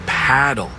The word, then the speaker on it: Padel: Wait, is that Padel? – No, that's paddle. Rhymes with saddle.